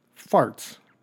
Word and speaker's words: Faartz: This guy's name, his last name, is spelled F-A-A-R-T-Z. Farts. Now, that's not bad enough. Huh? His first name is W-H-E-T.